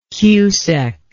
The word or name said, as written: cusec